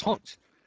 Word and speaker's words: Hans: I'm a German, and my name is not Hans, it's Hans.